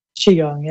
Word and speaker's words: Qigong: Qigong, right? – Qigong, yeah.